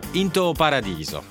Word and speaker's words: Into Paradiso: Intanto è stato presentato Into Paradiso. Già, si pronuncia così, così mi ha detto Marta Cagnola, di Paola Randi, con Gianfelice Imparato e Peppe Servillo, già celebrato a Venezia e nelle sale il prossimo weekend.